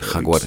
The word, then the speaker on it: Jaguares: Euh, là, on voit par les résultats de leur province qui sont euh, les Jaguars, vous m'excuserez pour la prononciation. Euh, Jaguares. Qui, euh, voilà, les Jaguares, qui, euh, ils ont des résultats en hausse, ils, ils commencent à battre les Néo-Zélandais en Nouvelle-Zélande.